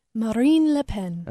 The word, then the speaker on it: Marine Le Pen: Marine Le Pen. – Okay. She said, you have to say it the French way.